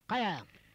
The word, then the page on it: kaya: Noongar